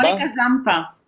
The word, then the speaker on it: Monika Zampa: Monika Zampa.